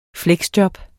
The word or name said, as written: fleksjob